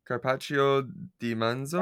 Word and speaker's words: carpaccio di manzo: I think I would do the carpaccio di manzo.